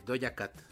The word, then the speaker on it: Doja Cat: Y la canción es Woman, de Doja Cat.